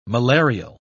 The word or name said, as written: malarial